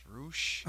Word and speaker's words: Rush: Rush takes on Dalton Castle in a no DQ match. – Isn't it pronounced Roosh? – Roosh, you're right. Roosh. I apologize. You are right.